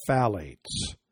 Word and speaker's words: phthalate: It defies normal spelling. It's pronounced phthalate. I had to look it up. It's P-H-T-H. P-H-T-H-A-L-A-T-E-S.